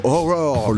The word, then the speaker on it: Ho99o9: bien ça, ça tabasse pour la fin, le mélange rap et métal en plein essor aux États-Unis avec juste avant Horror, le groupe qui s'écrit H-O-9-9-O-9